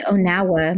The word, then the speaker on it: Onawa: It rhymes with Onawa, my dog.